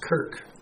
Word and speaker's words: Kirche: From there, it went to several different languages, including Old English and German, with the word spelled K-I-R-C-H-E, which is pronounced Kirk. In Scotland, it's spelled K-A-R-K.